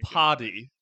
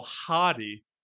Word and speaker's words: haughty: You said very potty. Good. – Yeah. – Like the toilet. – Haughty. – Oh, haughty.